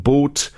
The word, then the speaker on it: Both: Original lineup featured Hans Tim Hinrichsen, Axel Storsberg, Björn Boat. That's been called, it's actually B-O-T-H, but I suppose you pronounce it boat.